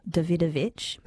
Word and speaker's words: Davidovici: That track is Peace in the Middle East by Michael Davidovich, but it's spelt Davidovici, so David and then O-V-I-C-I.